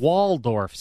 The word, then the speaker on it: Waldorf: A Waldorf salad.